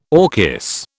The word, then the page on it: orchis: British English